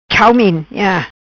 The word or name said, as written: Chau Min